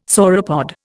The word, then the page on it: sauropod: Indian